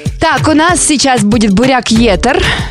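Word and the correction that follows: Burak: Не «Буряк», а «Бурак».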